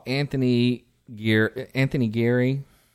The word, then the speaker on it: Anthony Geary: And I mean, I thought Anthony Geary, is that how you pronounce his name? – Yeah.